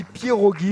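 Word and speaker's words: pierogi: Les pierogues. – Ah oui, c'est ça, c'est les pierogues ou pierogues. Mais je crois qu'on dit pierogues, j'ai vérifié la prononciation.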